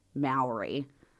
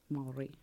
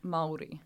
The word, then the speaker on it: Māori: And just to quickly interject here, in Australians, we're taught to pronounce it Maori. – Maori. Yeah. – And that's wrong. – Yeah. So it's you roll your R's. – Maori. – Maori.